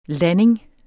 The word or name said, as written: landing